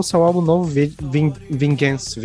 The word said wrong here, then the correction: Vengeance: Vingance, sei lá como é que pronuncia essa porra. Mas é isso daí, a Vingança. Vengeance. Vengeance.